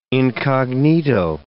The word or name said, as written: incognito